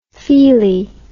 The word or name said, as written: feelie